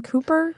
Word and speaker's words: Cowper: It was William, I think, William Cooper, C-O-W-P-E-R. – Is it William? Cowper. – It's pronounced C-O-W. Cooper. – Oh, yeah, C-O-W-P-E-R, as far as I know.